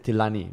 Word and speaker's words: Tilani: Like my last name is Tilani. Very, very syllables all very clear. Yeah. I've been called Tinali so many fucking times.